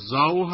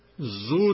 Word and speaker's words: زاو: به هر حال این در اصل تلفظش اینطور که می دانم زاو هست، زاو پسر طهماسب، و که روی هم رفته زاو طهماسب هم گفته میشه. اما اگر جایی زو تلفظ شده باشه، به ضرورت قافیه دیگر واژه دیگری بوده که در قافیه دیگر اون بیت آمده بوده.